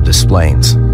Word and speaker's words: Des Plaines: I grew up in a family of 10 kids in the Chicago suburb of Des Plaines. That's how they pronounce it in Illinois.